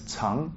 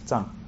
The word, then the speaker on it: 藏: Sometimes this word is also pronounced as chang, yeah, that it can hide, it can store. Yeah. 人常所常职常善意 yeah, but uh, I'm more used to pronouncing it as zhang.